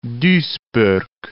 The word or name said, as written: Duisburg